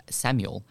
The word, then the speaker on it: Samual: But instead of spelling it I-S, I'm going to go A-S because my boy name, surprise, is Samuel. But it's spelled S-A-M-U-A-L.